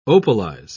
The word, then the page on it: opalize: British English